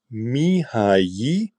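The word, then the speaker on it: Mihály: Also, ganz langsam, Mihai. Dieses LY wird im Ungarischen Ji ausgesprochen. Cheek, weil auf dem I ein Apostroph ist, wird der lang ausgesprochen.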